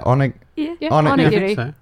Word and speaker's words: Onigiri: And if you miss the Onigiri, it would... is that how you say it? – Onigiri.